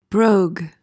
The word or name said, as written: brogue